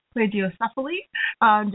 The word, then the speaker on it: plagiocephaly: For 8 of the years that Jennifer's been working, she's been involved in treating infants, babies with, I had to look up this word to make sure that I was pronouncing it correctly, plagiocephaly. Jennifer will correct me.